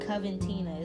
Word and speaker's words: Coventina: And then I guess it's pronounced Aine, A-I-N-E, and Coventina is literally just like the word coven and then Tina.